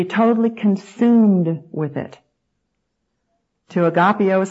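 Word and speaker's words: agapao: Now, the Greek word for the word love here, used in both of these great commandments, is the verb agapeo. Let me spell that for you. It's A-G-A-P-A-O, agapeo.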